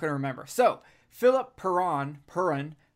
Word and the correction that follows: Perrin: Perron, Perron, Perrin. – Perrin. Perrin.